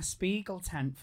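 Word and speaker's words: Spiegeltent: I was literally trying to entertain a Spiegel tent full of... Have I said it right? – Yeah.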